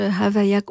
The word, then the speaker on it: Havaya: C'est Havaya avec un H, Havaya. On le prononce très, très doucement.